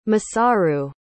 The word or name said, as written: Masaru